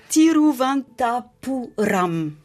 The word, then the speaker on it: Thiruvananthapuram: Mais je ne sais même, je saurais même pas le Thiruvananthapuram.